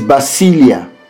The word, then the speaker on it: basileia: In the New Testament of the Bible, the Greek word used for kingdom is basileia, and it is spelled B-A-S-I-L-E-I-A, basileia.